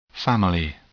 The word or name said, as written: family